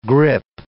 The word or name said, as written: grip